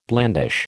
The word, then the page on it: blandish: American English